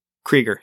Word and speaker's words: Krygier: Is it just Krieger? – Spell it. If it's the K-R-Y- one I'm thinking of, you said K-R-Y-G-I-E-R. – Krieger, yeah.